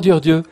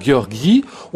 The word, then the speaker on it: György: Bonsoir Dior Dieu. – Bonsoir, monsieur. – Euh, je prononce après correctement. Là, c'est vous qui m'avez dit tout à l'heure. C'est pas Georgi. On peut dire Georges.